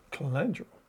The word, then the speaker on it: Calendula: Calendula. – Is that how you say it? – That's good enough for me. – That's how you say it.